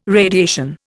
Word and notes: radiation: Indian

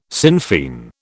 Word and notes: Sinn Fein: British English

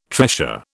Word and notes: tressure: British English

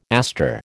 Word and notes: aster: American English

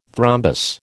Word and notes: thrombus: American English

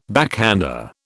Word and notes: backhander: British English

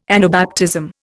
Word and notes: Anabaptism: Indian

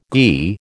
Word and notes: ghee: American English

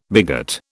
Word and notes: bigot: British English

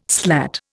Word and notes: slat: Indian

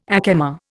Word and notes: ack emma: Indian

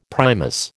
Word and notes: Primus: American English

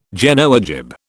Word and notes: Genoa jib: British English

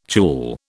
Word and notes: tulle: British English

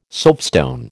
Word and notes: soapstone: American English

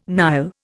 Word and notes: Nile: Indian

